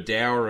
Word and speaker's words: dour: Dour. It rhymes with sour, mate.